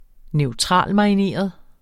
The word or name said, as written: neutralmarineret